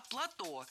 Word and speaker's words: плато: Не плата, а плато. Слово это тоже французское и тоже сохраняет ударение на последнем слоге. Причем плато – существительное неизменяемое и всегда во всех формах звучит одинаково – плато.